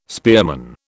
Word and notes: spearman: British English

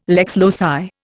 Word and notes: lex loci: Indian

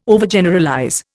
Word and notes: Overgeneralize: Indian

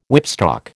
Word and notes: whipstock: American English

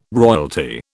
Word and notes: royalty: British English

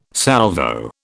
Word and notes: Salvo: British English

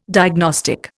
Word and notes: diagnostic: Indian

